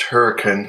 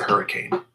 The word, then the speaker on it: Hurricane: Maybe they're British and they think it should be pronounced Hurricane because that's the British pronunciation of it. Maybe they're, yeah, that's a good point. Maybe they're from England. – Yeah, but not here. That's in Utah, so we pronounce it Hurricane.